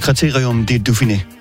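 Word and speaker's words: Critérium du Dauphiné: Fordi i går, der sad jeg så Criterium de Dauphiné. – Mm. Ej, flot udtale.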